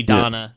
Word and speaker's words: Dana: Lacrimosa of Donna, but it's spelled like Dana, you know, but it's actually Donna.